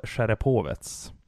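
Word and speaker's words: Sjerepovets (Череповец): Säsongen 15-16 med Severstal Sjerepovets. Mm. Hur tycker jag uttalar det förresten? – Ja, det var väldigt bra. – Ja, lite rysk touch ändå, va? – Ja, nej, men det var jättebra.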